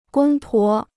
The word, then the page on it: 公婆: gōng pó